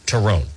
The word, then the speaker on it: torrone: Is it, Turone. Turone, T-O-R-R-O-N-E.